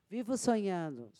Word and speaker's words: Vivo Sonhando: So Vivo Sonado. – Vivo Sonhando. – Sonhado.